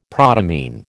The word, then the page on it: protamine: American English